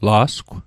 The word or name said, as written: la'sgw